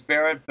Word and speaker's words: Barrett: Well, I think it's Barrett, but it really doesn't matter.